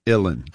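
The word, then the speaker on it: uilleann: It's Ilin. Like chillin, Ilin.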